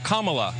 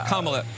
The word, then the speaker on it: Kamala: Her name is pronounced comma, like the punctuation mark. Look, Kamala. Okay? Seriously, I've heard every sort of bastardization. – So what? – That's how it is, Kamala.